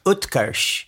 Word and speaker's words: Utkarsh: It's a soft T. The name is Utkarsh.